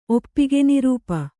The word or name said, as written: ಒಪ್ಪಿಗೆನಿರೂಪ